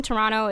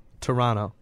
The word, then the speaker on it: Toronto: Like, Americans will say Toronto and pronounce every single word, but if you're from Toronto, it's just one quick word. It's more of an A. – Wait. You're saying we say Toronto? – A lot of Americans will like overpronounce Toronto. – It. – Toronto. – That's closer to how we say it.